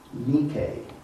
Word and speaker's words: Nike: It is pronounced Nike. Maybe you're more familiar with the tennis shoe brand. That's the same.